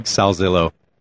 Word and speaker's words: Salzillo: So the name is Greg Salzillo.